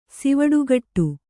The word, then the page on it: ಸಿವುಡುಕಟ್ಟು: kannada